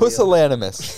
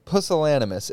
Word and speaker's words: pusillanimous: I love pusillanimous. The word is pusillanimous, and that is spelled PUSILLANIMOUS. Pusillanimous. – Pusillanimous. Like anonymous.